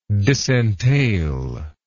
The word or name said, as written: disentailment